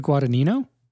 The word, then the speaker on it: Guadagnino: How do you pronounce his last name? – Luca Guadagnino.